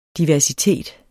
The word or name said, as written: DIVERSITET